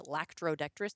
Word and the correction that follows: latrodectus: There's no C in it. – Man, who cares? – It's latrodectus. That shows like the infrastructure and how this works.